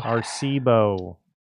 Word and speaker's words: Arecibo: Oh, is it Arecibo? – Arecibo. – I believe it's pronounced.